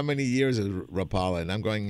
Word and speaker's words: Rapala: Uh, we're, we're slowly, slowly getting uh, Americans to say the name of that lure company right, which is Rapala. And actually, it's that guttural northeastern European that uh, Americans can't even really pronounce.